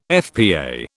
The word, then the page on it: FPA: British English